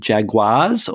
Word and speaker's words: jaguar: Well, my first news story, is about, and you'll have to help me with the pronunciation here, is do you call them jaguars or jaguars? – Jaguar. – Jaguar.